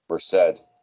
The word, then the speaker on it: Merced: M-E-R-C-E-D, which is in California. – Merced. – Oh, is that what it is? – Yeah, they pronounce Merced.